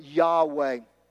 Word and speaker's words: Yahweh: In the Old Testament scriptures, the name written for God is the name, we would pronounce it Yahweh.